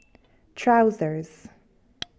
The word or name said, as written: trousers